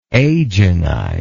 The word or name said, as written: agenize